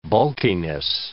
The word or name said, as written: bulkiness